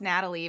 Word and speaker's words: Nathalie: Because that's how you spell Natalie in French, but it's not pronounced Nathalie. – Yeah. – It's still pronounced Natalie, even though it has the TH.